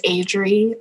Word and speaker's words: Adrie: Hello, my name is Adri. That's spelled A-D-R-I-E.